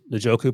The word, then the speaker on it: Njoku: By the way, I know why you pronounce Njoku properly, because you're all about no jokes.